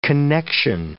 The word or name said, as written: connexion